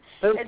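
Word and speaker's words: Pokah: Puka, okay. – It's P-O-K-A-H.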